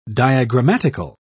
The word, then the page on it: diagrammatical: British English